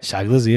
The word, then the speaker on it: Shy Glizzy: And then with Shy Glizzy. That's how you pronounce it, right? – Shy Glizzy, yeah.